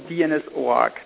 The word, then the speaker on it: OARC: So you know DNS OARC, do you? – No. – Okay. Am I just pronouncing it wrongly, or does nobody else tell? – You are pronouncing it correctly.